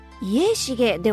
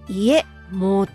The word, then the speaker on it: 家茂: おうちの家という字に草が生い茂るの茂ると書いて、家茂ではなくてね、家茂って読むんだよ。茂るっていう字は茂とも読むんだね。